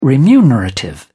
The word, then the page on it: remunerative: British English